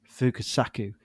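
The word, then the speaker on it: Fukasaku: Fukusaki, sorry if I'm butchering his name. Is it Fukusaki? – Fukusaku.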